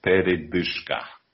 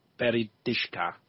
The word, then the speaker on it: Передышка: Передышка. – Передышка. – Передышка. – Передышка. – Yeah. Be careful. If you mispronounce it, then it becomes передышка, which is like a farter.